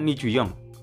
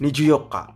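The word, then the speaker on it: Nijuyoka (二十四日): Niju harusnya kan Niju Yong, tapi bacanya Niju Yok, Pak. Tanggal 24 Niju Yok, Pak, nggak ada Nichinya.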